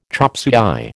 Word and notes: chopsuey: American English

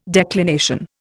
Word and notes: declination: Indian

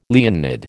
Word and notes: Leonid: American English

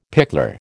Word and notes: pickler: American English

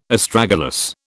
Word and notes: Astragalus: British English

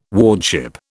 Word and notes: wardship: British English

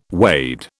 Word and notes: wade: British English

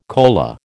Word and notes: kola: American English

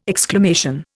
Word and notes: exclamation: Indian

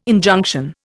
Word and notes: Injunction: Indian

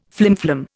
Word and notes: flimflam: Indian

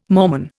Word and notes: Mormon: Indian

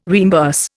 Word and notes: reimburse: Indian

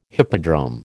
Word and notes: hippodrome: American English

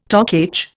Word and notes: Toc H: Indian

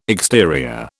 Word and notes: exterior: British English

